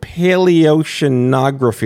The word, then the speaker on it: paleoceanography: I do want to start by saying that I've never, ever pronounced the word paleoceanography. Did I get that right, Alice? – Yes, that's correct.